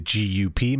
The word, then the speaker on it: GUP: Is it GUP magazine? I've never understood how to say it correctly. – If you are Dutch, it's Gup.